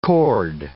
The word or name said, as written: cord